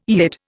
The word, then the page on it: Yid: Indian